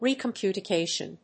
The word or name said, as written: recomputation